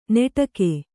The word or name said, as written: ನೆಟಗೆ